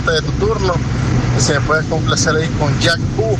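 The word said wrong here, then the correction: Jack Ü: de Skrillex y Diplo, o sea, Jack U, algo así, entonces acabas de pronunciar. Ahí estamos, cuídate, brother. – Hey, saludos, José David, así mero, así se pronuncia, Jack U.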